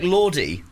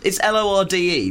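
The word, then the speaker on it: Lorde: It was a bit like Lordy, which is somebody who I heard about yesterday.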